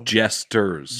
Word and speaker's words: jesters: Court gestures. How do you say it? Gestures. – You got called out a lot for people. Jesters. – Jesters.